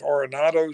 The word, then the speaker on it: Arenado: You got the third baseman, is Arenado, is that pronounce it? Yep.